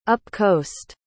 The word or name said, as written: upcoast